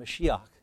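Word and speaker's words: Mashiach (משיח): And it simply, or if you want the Hebrew pronunciation, Mashiach.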